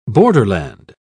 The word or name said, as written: borderland